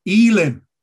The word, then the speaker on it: Elim: You remember Elim, that's how the Hebrews would pronounce it, Elim, with its 70 palm trees and all its wells of water.